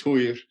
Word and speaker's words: Týr: All right, so this time we are joined by Harry Jonsson from the awesome Faroese band Týr, spelled T-Y-R. I'm doing my best to pronounce it in Faroese, but other pronunciations, I guess, would be Týr or Týr.